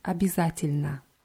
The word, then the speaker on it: обязательно: Ja, wir nehmen noch ein neues Wort dazu, обязательно. Обязательно heißt unbedingt. Wird mit Ja hinterm B geschrieben, also der dritte Buchstabe ist Ja, ist aber eher wie I ausgesprochen. Обязательно.